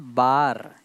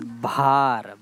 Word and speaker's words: भार: It's not bar, it's bhar.